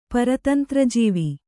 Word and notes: ಪರತಂತ್ರಜೀವಿ: Kannada

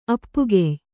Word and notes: ಅಪ್ಪುಕೆಯ್: appukey

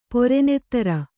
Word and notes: ಪೊರೆನೆತ್ತರ್: pore nettar

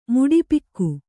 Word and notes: ಮುಡಿಪಿಕ್ಕು: Kannada

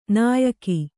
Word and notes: ನಾಯಕಿ: nāyaki

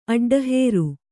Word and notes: ಅಡ್ಡಹೇರು: aḍḍahēru

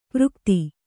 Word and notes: ಪರಾಗತಿ: parā gati

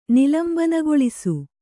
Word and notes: ನಿಲಂಬನಗೊಳಿಸು: nilambanagoḷisu

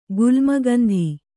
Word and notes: ಗುಲ್ಮಗಂಧಿ: Kannada

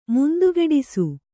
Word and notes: ಮುಂತುಗೆಡಿಸು: muntugeḍisu

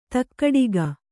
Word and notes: ಧಕ್ಕಡಿಗ: dhakkaḍiga

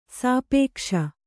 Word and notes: ಸಾಪೇಕ್ಷೆ: sāpēkṣe

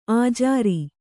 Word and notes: ಅಜುರ: ajura

